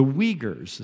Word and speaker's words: Uyghurs: Again, one of the prayer requests here mentions China, and maybe you had a little trouble pronouncing that word if you haven't heard us say it before. The Uyghurs is how you say that.